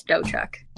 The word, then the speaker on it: Dotchuk: Emma Jane Dotchuk. – That is incorrect. – What? What is it? – So it's Emma Jane and the T is silent, so it's Dochuk. It's not Dotchuk.